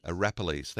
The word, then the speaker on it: Arapiles: Rock climbers at Mount Arapilis, I think that's how you pronounce it, excuse my pronunciation. Arapiles, thank you.